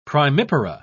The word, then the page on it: primipara: British English